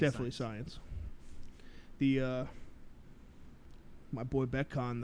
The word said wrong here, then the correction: Mekhi Becton: though. – Mekhi. – Oh. – Mekhi Becton. – Oh. – Beast. – I'm obsessed with this dude, and I still can't get his name right, and I'm going to butcher it forever, probably, until I buy his jersey. – Pretty sure it's Mekhi Becton.